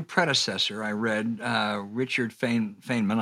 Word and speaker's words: Feynman: Yeah, it's Feynman, how he pronounced it.